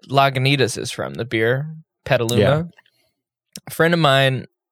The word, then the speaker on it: Petaluma: And I was like, No, Petaluma. That's how it's pronounced. But then I looked at the word and I was like, you could very much pronounce that Petaluma and it would absolutely make sense.